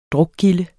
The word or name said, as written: drukgilde